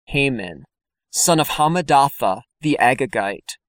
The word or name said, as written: Haman Hammedatha Agagite